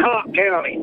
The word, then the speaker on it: Cocke: And up in, I guess it's Cook County, Tennessee. I don't know how you pronounce it. C-O-C-K-E. – It's Cock County, like a fighting rooster.